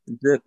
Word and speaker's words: Zyp: Did I pronounce that right? – Correct. Yes, awesome. Zip. It's like an I, but it's a Y.